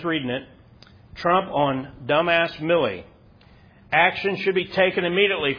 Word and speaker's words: Milley: Is his name pronounced Miley or Millie? Millie Vanilli. That's how you remember.